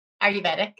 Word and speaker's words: Ayurvedic: but Ayurvedic. How do you say that? – Ayurvedic, yeah.